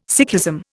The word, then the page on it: Sikhism: Indian